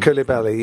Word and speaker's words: Koulibaly: Koulibaly, yeah.